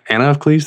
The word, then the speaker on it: Anna of Cleves: Anna of Cleves, did I say that right? – That's it.